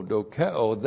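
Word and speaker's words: dokeo (δοκέω): D-O-K-E long O, dokeo, that's subjective thinking.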